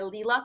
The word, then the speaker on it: Lila: So my name is Galila, but in social media, I go by Lila Talks. It's spelled L-I-L-A, Lila Talks, L-I-L-A, Lila Talks.